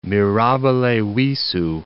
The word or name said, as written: mirabile visu